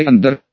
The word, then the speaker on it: Андер: Дорогой Андер, обратился ко мне помощник, используя новое имя, и даже правильно сделал ударение, на последний слог.